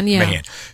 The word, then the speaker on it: Mahan: Mahan.